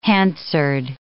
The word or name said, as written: Hansard